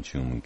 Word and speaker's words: Tuam: Oh, by the way, just for anybody foreign, perhaps, who's reading, we pronounce T-U-A-M. Most people here will pronounce it as Tume. I know it's probably Tume, but we generally say Tume as if it's a C-H.